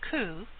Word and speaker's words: coup: There is a term called Indian coup, pronounced coup, spelled C-O-U-P, as in gotcha, which can be translated into win and you lose.